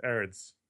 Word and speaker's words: Gerrits: If this kind of thing tickles, the G is silent. It's Gerrits.